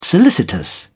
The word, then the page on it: solicitous: British English